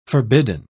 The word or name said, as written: forbidden